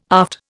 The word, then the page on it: aft: Indian